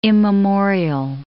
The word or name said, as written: immemorial